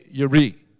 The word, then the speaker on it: Jireh: But Jehovah Jireh, they would say Yireh. But we pronounce it Jehovah Jireh because that sounds good to us.